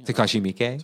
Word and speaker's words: Takashi Miike: I've started getting into, Takashi Mike or Takashi. – Takashi Miike? – Takashi Miike. – Yeah. – Pronounced it wrong.